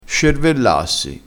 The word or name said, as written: scervellassi